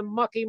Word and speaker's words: Muck: gonna get out there in the mucky mucking. And I was like, you know, all right, I like that word, muck. It rhymes with fuck.